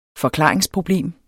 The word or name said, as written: forklaringsproblem